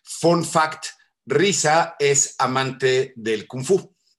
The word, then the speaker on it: RZA: Como bien mencionaste, Cafa, el, este, bueno, RZA, que se, pronuncia Risa, Risa,